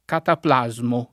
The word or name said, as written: cataplasma